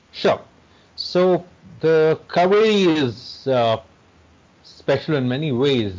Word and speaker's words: Kaveri: one of the rivers that you love is spelled Cauvery. – Yes, it's the Kaveri.